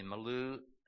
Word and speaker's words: milieu: Abraham was born in the Milieu. How do you say M-I-L-I-E-U? Huh? Milieu? I wrote it, I just don't know how to say it.